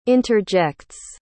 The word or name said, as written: interjects